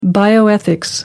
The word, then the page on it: bioethics: American English